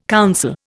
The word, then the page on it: counsel: Indian